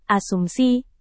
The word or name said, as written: asumsi